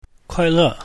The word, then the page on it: kuai4le4: mandarin